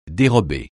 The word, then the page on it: dérober: francais